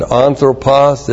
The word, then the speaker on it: anthropos: And anthropos is the generic term for mankind. A-N-T-H-R, long O-P-O-S.